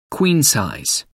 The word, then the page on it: queen-size: American English